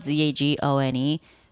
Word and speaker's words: Zagone: Cause my Instagram is like, it's spelled Zagone. So it's like Zag one. So it's like Zag and then the number one.